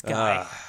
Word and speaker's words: Guy: I know it's pronounced Guy because it's like French, but... – Yeah, it's Guy.